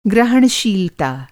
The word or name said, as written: ग्रहणशीलता